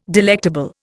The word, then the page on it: delectable: Indian